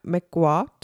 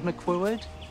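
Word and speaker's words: McQuoid: Je to Tanya McQuad. – Are you Ms. McQuad? – Quad. One syllable. – McQuad. – Well, two syllables. But the second part is one syllable. Quad. McQuad.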